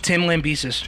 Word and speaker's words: Lambesis: Tim Lambesis.